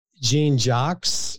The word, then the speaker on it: Jean-Jacques: Dennis Jean Jacques, if I said that correctly, is it Jacques or Jax? What's the French pronunciation? – Jean-Jacques.